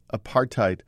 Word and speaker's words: apartheid: "I believe apartheid rhymes with hate.